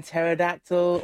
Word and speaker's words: pterodactyl: K in knock, the P in pterodactyl, all coming through.